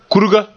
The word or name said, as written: kurga